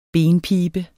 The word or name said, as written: BENPIBE